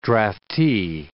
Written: draftee

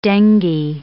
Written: dengue

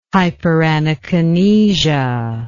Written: hyperanakinesia

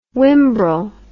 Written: wimbrel

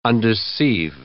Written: undeceive

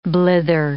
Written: blither

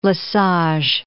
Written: Lesage

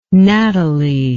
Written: Nathalie

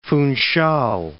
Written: Funchal

